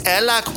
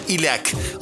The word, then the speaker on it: Ellák: Az Ellák Attila Hun uralkodó fiának a neve, téves olvasatban terjedt el, a helyes kiejtése Ilek.